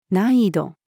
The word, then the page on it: 難易度: female